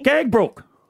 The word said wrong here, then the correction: Gagebrook: Is that how you say it? – Gagebrook. – Really? – Okay.